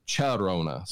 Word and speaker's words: Llorona: But they pronounce it La Llorona, Charona. So I guess it's like the local pronunciation of the LL is what I looked up and saw. But it was interesting every time I heard it because I kept expecting the ya and it was cha.